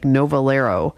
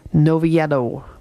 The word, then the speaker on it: Noviello: If you say it in an anglicized version, it would be like Novalero. But if you have a Spanish accent, it would be Noviello.